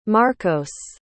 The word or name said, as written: marcos